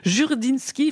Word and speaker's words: Jurdinski: Jurdinski, il faut le prononcer à la française.